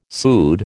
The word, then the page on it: pseud: American English